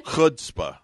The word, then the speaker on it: chutzpah: Did she have a lot of hoopspa? Did I say that right? Hoopspah? – You were close. Chutzpah.